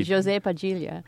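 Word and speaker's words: José Padilha: Portuguese? If you want to be Brazilian about it, say Jose Padilha.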